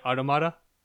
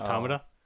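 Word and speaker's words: NieR: Automata: Because I was thinking, there was this thing in, I always forget how to pronounce it. I think it's Nier Automata, not Automata.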